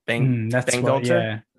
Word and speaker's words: Bangalter: Bangalter.